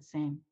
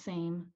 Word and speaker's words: SĀM: is um it's a, it's a play on the phonetic spelling of the word same. So it, it just it's pronounced same. A lot of people um, want to say Sam.